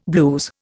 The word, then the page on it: blues: Indian